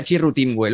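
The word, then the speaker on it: Chirrut Îmwe: El pack Rogue One, con Jyn Erso, Bodhi Rook, Cassian Andor, K2SO, Chirrut Imwe, que corrígeme si me equivoco. ¿Cómo se pronuncia Chirrut Imwe? ¿Lo estoy pronunciando bien esto, Juanma? – Sí, suena bien.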